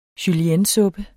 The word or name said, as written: juliennesuppe